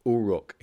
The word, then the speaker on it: Uruk: Now, Gilgamesh was the accepted historical king of the Sumerian city-state of Uruk, or Uruk, if you pronounce it that way.